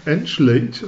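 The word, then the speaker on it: Engelage: Wie spricht man den eigentlich aus, muss ich mal kurz reinfangen. Andrew Angelage, oder? – Angelage, ja, genau.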